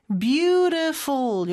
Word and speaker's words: beautiful: 그 다음에 beautiful인데 beautiful 이렇게 발음이 되죠. t는 모음 소리 사이에 껴서 리을처럼 발음이 돼요.